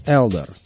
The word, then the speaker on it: Элдар: стал называться просто Элдар, с ударением на первый слог.